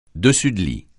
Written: dessus-de-lit

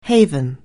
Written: haven

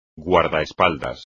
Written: guardaespaldas

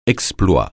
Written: exploit

